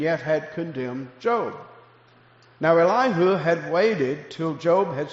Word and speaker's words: Elihu: Then was kindled the wrath of, and I'm going to call him Elihu. That's how I grew up pronouncing it. Some say Elihu, or I'll say Elihu, the son of Barachah, the Buzite of the kindred of Ram.